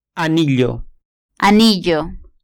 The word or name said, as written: anillo